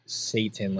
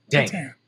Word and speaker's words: Catan: Is it pronounced Catan or is it Satan, like you were trying to say right there? – No, it's Catan.